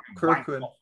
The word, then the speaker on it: Korakuen: Korakuen Hall, god damn it. You're the one who's supposed to be able to pronounce it. Korakuen.